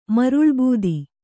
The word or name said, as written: ಮರುಳ್ಬೂದಿ